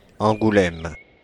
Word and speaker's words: Angoulême: Angoulême.